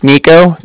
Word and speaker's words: Niko: Okay, so my name's Nico. That's spelled N-I-K-O.